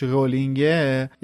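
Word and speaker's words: Rowling: چرا میگی رولینگ درستش رولینگه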